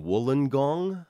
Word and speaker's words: Wollongong: And then I'm doing Wollongong. – Okay. The gong? They're good audiences in the gong. – Did I pronounce it correctly? – That was pretty good, actually, okay.